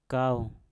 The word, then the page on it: kau: Mpur